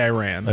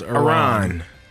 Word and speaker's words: Iran: You're not supposed to say Iran. – I'm in America. – That's wrong. – This is America. – So it's supposed to be pronounced Iran.